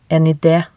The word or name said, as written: en ide